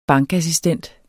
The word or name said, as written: bankassistent